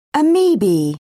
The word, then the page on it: amoeba: British English